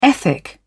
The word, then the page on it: ethic: British English